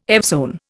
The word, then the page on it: evzone: Indian